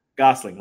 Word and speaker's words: Gosling: Gosling, Ryan Gosling.